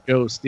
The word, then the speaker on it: Jost: Is that Yost? Jost? How do you say that? – Yost, yeah.